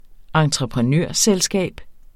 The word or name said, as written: entreprenørselskab